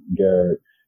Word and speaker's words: Garrett: And you just simply just search with my first and last name, Lionel Garrett. My first name is spelled L-I-O-N-E-L, and my last name is G-A-R-R-E-T-T.